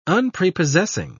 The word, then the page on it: unprepossessing: British English